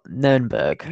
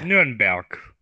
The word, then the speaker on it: Nuremberg: Is it would you say Nuremberg or Nürnberg? – Nuremberg is pronounced.